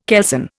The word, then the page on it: kelson: Indian